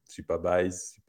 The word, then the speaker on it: Supabase: Alors, euh, pour ceux qui connaissent, bah, Supabase, c'est, euh, Supabase,